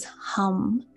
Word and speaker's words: HAM: This one would be, it's spelled H-A-M, so like ham, but it's actually pronounced as hum, so like a hum.